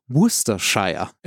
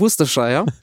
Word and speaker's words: Worcestershire: Royal Enfield war damals ein britischer Hersteller aus Redditch und jetzt bitte Aussprache Worcestershire, ja. – Da wo die Soße herkommt, ja. Die nicht Worcester heißt. So, aus Worcestershire.